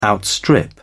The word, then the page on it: outstrip: British English